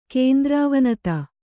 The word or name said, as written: ಕೇಂದ್ರಾವನತ